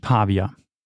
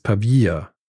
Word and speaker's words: Pavia: Also es ist nicht Pavia. Es heißt nicht Pavia. – Hm. – Es heißt Pavia.